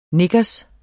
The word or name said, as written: knickers